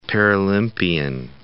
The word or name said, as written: Paralympian